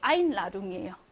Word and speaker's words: Einladung: 안으로 뭔가 초대하는 거니까 이렇게 안으로라는 의미가 있기 때문에 의미가 있는 분리전철은 당연히 세게 발음하죠. 여기 세게 einladung이에요. 몇 음절 강세야? 그치? 세 개의 음절 중에 얘네 1음절 강세예요.